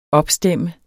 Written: opstemt

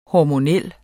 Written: hormonal

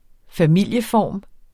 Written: familieform

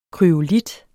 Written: kryolit

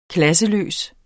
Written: klasseløs

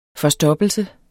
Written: forstoppelse

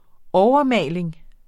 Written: overmaling

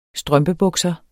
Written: strømpebukser

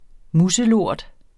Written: muselort

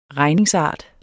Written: regningsart

